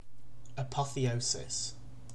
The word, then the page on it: apotheosis: British English